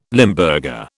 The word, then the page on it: Limburger: British English